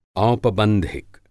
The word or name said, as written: औपबंधिक